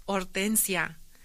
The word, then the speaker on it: Hortensia: Hortensia, that's my name, not Hortensia, it's Hortensia. I am tired of constantly telling and correcting folks that H is silent in Spanish. And you pronounce the O, Hortensia.